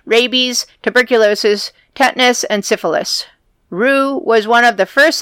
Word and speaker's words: Roux: How do you say it again? R-O-U-X? – I would pronounce it as a who, but Roux. Okay. He's considered the founder of the field of immunology and is a co-founder of the Pasteur Institute.